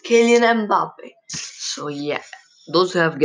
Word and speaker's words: Mbappe: Kylian Mbappe Lottin, L-O-T-T-I-N, is his full name, pronounced as Kylian Mbappe,